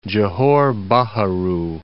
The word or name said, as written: Johor Baharu